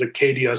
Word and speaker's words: acedia: Sometimes they pronounce it Acadia, so you'll hear it a variety of different ways.